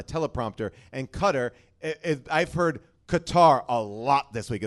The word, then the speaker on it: Qatar: There's that country, Qatar, or it's pronounced Cutter. – Cutter. But I've heard it pronounced both ways.